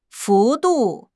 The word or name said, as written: 幅度